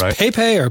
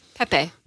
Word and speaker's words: Pepe: Is it pronounced PayPay or Pepe. – Pepe. – That's right.